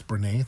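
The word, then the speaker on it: Brené: Brene Brown, I think it's Brene, that's how you pronounce it.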